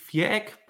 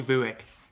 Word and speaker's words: Viereck: The German version would be Viereck, but you can call me Viereck.